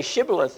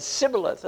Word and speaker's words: shibboleth: And they couldn't say shibboleth, they said sibboleth. And because they couldn't pronounce it, they knew these are the people that are the traitors, and they killed them.